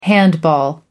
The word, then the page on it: handball: American English